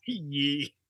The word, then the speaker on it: Ye: Where, how the fuck you say his name? – Ye. That's the Chinese Kanye. His name is Ye.